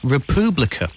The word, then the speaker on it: republika: Listening in the Czech Republic, and I'd like to let you know that it is actually pronounced Republika, which is basically what I said, says Alex Pavlova.